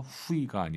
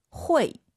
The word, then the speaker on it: 会: UI 사이에 에 넣어서 후이가 아니라 会.